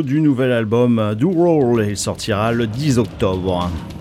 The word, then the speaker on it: Ural: On part maintenant en Italie avec le troisième album, Roll. Ça s'écrit U-R-A-L.